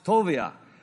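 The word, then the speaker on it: Toviah (טוביה): Even though he's from Ammon, he's an Ammonite, his name in Hebrew is actually spelled with a V, not a B, and it is pronounced Toviah.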